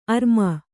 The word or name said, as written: ಅರಮ